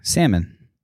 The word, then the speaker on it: salmon: I kind of am, because if S-A-M-O-N would be salmon. – Salmon. – If it was spelled S-A-M-O-N without the L, there's some sort of... – You'd pronounce it salmon. – There's some sort of like phonetics. – Wouldn't you? If I saw S-A-M-O-N, I would say mon. Mm-hmm. I wouldn't say salmon.